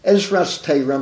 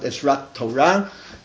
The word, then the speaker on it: Ezras Torah: Ezras Torah, I'm pronouncing it the way they do, not Ezrat Torah.